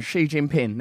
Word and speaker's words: Xi Jinping: I actually know how to say his name. I know as well. I've got it written down. Xi Jinping.